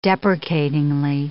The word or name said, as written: deprecatingly